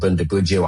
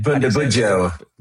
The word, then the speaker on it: Bundibugyo: So people might be hearing that there's this thing called Bundibugyo, hard to spell, hard to say. – How do you say it one more time? How do you say it? – Bundibugyo. Bundibugyo.